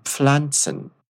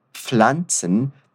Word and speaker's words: Pflanzen: Es passt sehr gut zu den Pflanzen. Wunderbar. Percebe que a pronúncia de Pflanzen tem um detalhe. Normalmente nós falamos o som da letra P no começo, mas ele é bem suave. Pflanzen.